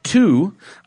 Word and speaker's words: Too: First, we have one from Too, T-O-O, and he said to pronounce it that way.